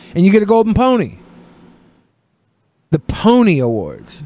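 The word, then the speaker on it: Pwnie: The name the Pony Awards, pronounced as pony, is meant to sound like the Tony Awards.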